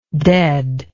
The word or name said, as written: deadness